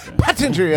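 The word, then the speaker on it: Pottinger: If I could say his name, Pattinger. – Pattinger, yes, that's the guy.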